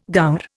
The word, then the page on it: gaur: Indian